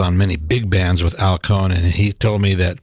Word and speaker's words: Cohn: That was Al Cohn, rhymes with saxophone,